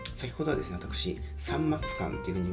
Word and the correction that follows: 三松館: に言いましたけど、えー、 3 つの松の館で三章館と読むそう